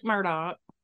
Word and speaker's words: Murdaugh: So Alec Murdaugh, which is not at all how you think his name is pronounced when you see it written, but that's how he pronounces it.